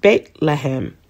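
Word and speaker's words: Beit Lehem (בית לחם): In Hebrew, the name is pronounced Beit Lehem.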